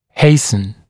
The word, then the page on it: hasten: ‘heɪsn; ‘хэйсн